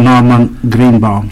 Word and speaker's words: Norman Greenbaum: Van Norman Greenbaum.